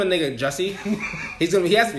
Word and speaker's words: Jussie: You said Jussie? – It's Jussie.